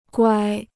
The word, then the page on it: 乖: guāi